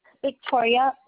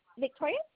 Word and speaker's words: Victoria: Victoria Ovalles. Last name is O-V-A-L-L-E. – Okay, hold on. Victoria, just, can you spell that for me, please? – Victoria? – Yes, ma'am. – Yes. V-I-C-T-O-R-I-A.